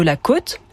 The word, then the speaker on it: côte: On entend ainsi la différence entre la côte, littoral par exemple, et la cote, les références indiquées sur un livre.